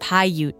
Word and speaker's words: Paiute: It's pronounced Paiute, young Misha made mountain places like this their homes, using the higher mountains for trade routes and summer encampment.